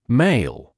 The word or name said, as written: male